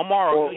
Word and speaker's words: Amaro: And if he can just get the ball in Percy Harvin's hands or Eric Decker's hands or Amaro's hands or Amos, I can't get his name right. I think it's Amos. – No, Amaro. You had it right, Amaro.